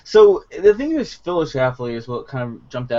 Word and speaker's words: Schlafly: Schlafly. – Schlafly? – Schlafly, yeah.